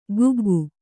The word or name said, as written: ಕುಗ್ಗು